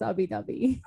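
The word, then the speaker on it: Ubbi Dubbi: My friends in Florida would like to disagree, but Disco Donnie literally put out a video saying it's Ubi Dubi.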